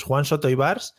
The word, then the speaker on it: Juan Soto Ivars: Bueno, vamos a hablar, eh, y lo escucharéis ahora, con Juan Tos ya lo digo mal por los nervios. Juan Soto y Bars eh,